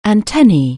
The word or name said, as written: antenna